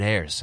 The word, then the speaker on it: Ayres: but last night, David Ayres, and I, I, believe I'm pronouncing his last name correctly. I believe you're correct, yes.